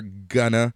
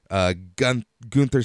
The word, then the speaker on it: Gunther: Bivens called Gunther Gunna. Gunther said it's pronounced Gunther.